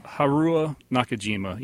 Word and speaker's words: Haruo Nakajima: I don't know how to say his name. Haruo Nakajima? – Haruo Nakajima. Yeah, the suit actor, the first suit actor of the first Godzilla.